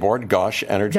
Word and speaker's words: Bord Gáis Energy: We're going to be there May 30th at the, how do you say it, Bord Gáis Energy Theatre.